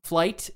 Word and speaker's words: Flyte: Flight, it's spelled F-L-Y-T-E. It's a reference to Sebastian Flight from Brideshead Revisited.